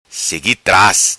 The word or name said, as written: Segui Tras